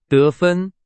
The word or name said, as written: 得分